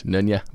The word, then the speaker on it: Nøgne: So it's written out Nogne. – Yeah. – N-O-G-N-E. – Come on. I know my home country's okay. pronunciation. No, I don't really. I just like, I pulled up a YouTube clip. – Googled it. – To hear the like head brewer or whatever give his pronunciation. And okay. I think it's Nunya.